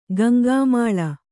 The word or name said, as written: ಗಂಗಾಮಾಳ